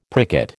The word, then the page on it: pricket: American English